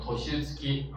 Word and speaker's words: 年月: 他说这个年月次的话，也可以念成 toshitsuki 啊，那么它这里用了什么？用了 tatsu 啊，经过的经啊，所以注意一下啊。所以这个单词的话，一般的都不会考它的读音的啊，因为它有两个读音都可以的啊，年月次和 toshitsuki 都是可以的。